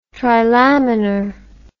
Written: trilaminar

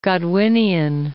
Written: Godwinian

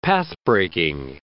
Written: pathbreaking

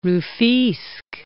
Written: Rufisque

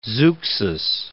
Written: Zeuxis

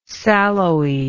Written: sallowy

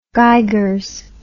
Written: geigers

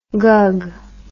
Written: gug

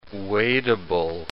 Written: wadeable

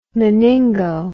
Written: meningo

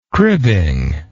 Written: cribbing

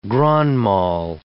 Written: grand mal

